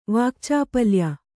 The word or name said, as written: ವಾಕ್ಚಾಪಲ್ಯ